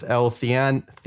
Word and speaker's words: L-theanine: And then the sleep one, which is interesting, is oatmeal raisin sleep cookie infused with L-theanine. Is that how you say it? – You got it.